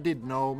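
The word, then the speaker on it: Llandudno: Eh, de var, var, det uttalas Didno men stavades Landudno tror jag.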